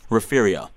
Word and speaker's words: Refugio: And Refugio.